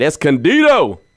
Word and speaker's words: Escondido: Now, my man Tom from Escondido, got to say that right, Escondido, he's calling about his favorite Raiders game and actually gives us two of them.